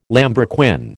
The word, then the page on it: lambrequin: American English